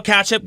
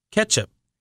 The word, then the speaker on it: ketchup: Yeah, it doesn't have an A in it. – Ketchup. – There it is. – Ketchup. – That's so ugly. It's ketchup.